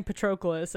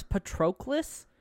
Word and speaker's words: Patroclus: There's no L-U or U-L-U-S. It's C-L-U-S? – Okay, well, that's even worse. Patroclus? – Patroclus. – Patroclus. That sounds like choke me. – Well, he probably says Patroclus. Like, pronounces the U more than, like, as a U than an E.